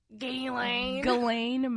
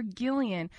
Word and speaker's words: Ghislaine: Ghislaine Maxwell. – What? Wait, what did we say? Jillian? – And I kept calling her Gillian.